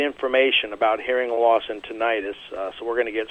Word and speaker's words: tinnitus: It's one of the largest categories of disabilities that affect military veterans, and that's hearing loss and also a related disability of tinnitus, or as some pronounce it, tinnitus.